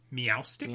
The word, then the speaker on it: Meowstic: Meowstic? – Yes.